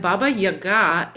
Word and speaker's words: Baba Yaga: And Baba Yaga is the Russian pronunciation. But what we find is in all the Slavic countries, there's a version of Baba Yaga. And it could be Baba Yaga, it could be Yedzi Baba, it could be there's all these different names, but they all have common characteristics.